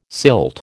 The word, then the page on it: silt: American English